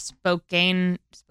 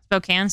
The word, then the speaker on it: Spokane: In 1910, Sonora Smart Dodd of Spokane, is that how you say it? Washington? – Spokane, sorry.